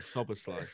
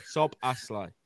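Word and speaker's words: Szoboszlai: So Bazlai, I reckon I've nailed it. – Sob-a-sly. Sob-a-sly. – Right.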